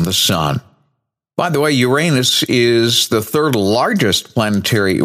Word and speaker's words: Uranus: And I can still remember hearing Tom Brokaw of NBC News pronouncing it as Uranus and thinking, it's not Uranus, it's Uranus. And then, of course, after time, understood the reason why he wanted to go to an alternate pronunciation. There is also one other alternate pronunciation, and that is Uranus. However, again, I'm going to head back to the astronomically approved pronunciation, Uranus, and we will go from there.